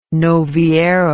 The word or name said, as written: novillero